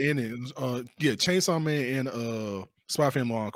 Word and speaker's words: Spy x Family: It's Spy X Family, but it's pronounced Spy Family.